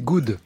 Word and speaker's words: Good: En attendant, je bosse ici et je ne peux que me réjouir de la réouverture de la fondation God Planet pour laquelle... – Non, c'est, c'est good. – Oui, mais en français, deux os, ça se prononce. Oh.